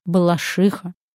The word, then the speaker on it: Балашиха: А правильно ставить ударение в названии этого города именно так – Балашиха. Не обижайтесь, жители города. Русский язык любит ударение на «а».